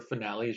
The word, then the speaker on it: Finale: finally, or finale, as you would pronounce it.